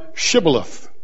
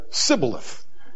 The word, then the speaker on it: Shibboleth: And so verse 6 says, they said to them, say now Shibboleth. And he said, Sibboleth. Couldn't get that H in there. He couldn't say Shibboleth. He said, Sibboleth, for he could not frame to pronounce it right.